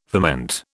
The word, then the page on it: ferment: British English